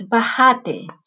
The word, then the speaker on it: bajate: So he says, bajate, with the stress on the second A, which means that it's to get out, but in the way that they speak in Medellin.